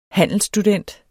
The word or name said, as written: handelsstudent